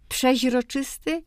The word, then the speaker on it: przeźroczysty: Od razu podam wyrok i tak, i przeźroczysty, i przezroczysty. W języku potocznym, ogólnym obie formy są mniej więcej jednakowo często spotykane.